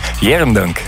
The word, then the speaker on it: Arendonk: Weet u dat ze in Arendonk, Arendonk uitspreken? – Nee, wat is iets wat... Zeg eens. – Jerendonk. – Jerendonk.